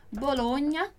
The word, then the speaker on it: Bologna: Por isso que, por exemplo, a gente tem lá a palavra, a cidade, famosa cidade na Itália, Bologna, que se escreve assim, Bologna, com GN no italiano. E no português, a gente escreveria Bologna. Mas a pronúncia é exatamente a mesma, ok?